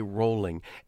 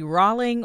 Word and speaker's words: Rowling: And before we get started, I have to tell you, I went to great lengths to find out how to pronounce this woman's name. – And so it could be Rowling or Rowling, which one? – She said, actually found a video of her pronouncing her name and saying that it's really J.K. Rowling, but so many people say Rowling